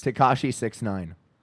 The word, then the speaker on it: Tekashi 6ix9ine: It's Tekashi 6ix9ine.